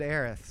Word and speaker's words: Aerith: And you pronounce it Aerith, so good for you. – Oh, thank you. – I think that's the correct Anglicization of that name.